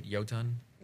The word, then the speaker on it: Jotun: Guild Wars 2 writer Ree Sosby had a blog post about the Jotun race. That's how you pronounce it, right? Jotun? – Jotun.